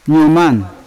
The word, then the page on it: nyoman: Yawa